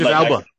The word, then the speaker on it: Elba: I don't know why I'm like, Idris Elba. Yeah, okay. – Yeah, I'm going Alba. I'm thinking that was the other way.